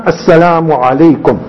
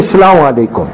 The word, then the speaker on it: assalamu alaikum: Our salam, assalamu alaikum. – What a beautiful. And very important also, pronounce the hamza, what we call the alif at the beginning. Don't say assalamu alaikum.